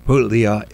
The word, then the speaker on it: Puglia: and and Puglia is the way we do. There's actually a G in there. It's actually a soft G, not a silent G.